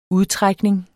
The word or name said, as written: udtrækning